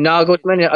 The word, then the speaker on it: Nagelsmann: Julian Nagelsmann, if, if, if, my pronunciation is correct it's there.